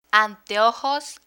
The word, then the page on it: anteojos: spanish